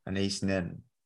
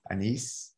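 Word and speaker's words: Anaïs Nin: That's by, I never know how to say her first name, Anais Nin. – Anais Nin. – Yeah. Thank you. – Yes. – Not close. – You know what you said, Anise.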